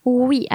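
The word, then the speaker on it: ouïe: Oui, alors not the yes, oui, but although it sounded the same, it's spelled O-U-I-tréma-E. So O-U-I with the 2 points on it, like the umlaut, and E.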